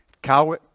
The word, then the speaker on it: Kauai: Kauai.